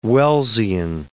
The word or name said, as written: Wellsian